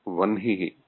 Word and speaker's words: वह्निः: वैदिकमते तु वह्निः उच्चारणे अस्माकं वेदोच्चारणम् अनन्तरं शिक्षाग्रन्थाः एव प्रमाणम् अतः ते तु तथैव उच्चारयन्ति वह्निः ब्रह्मा ब्राह्मणः पूर्वाह्नः मध्याह्नः इत्येव उच्चारणं भवति